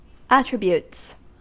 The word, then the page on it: attributes: American English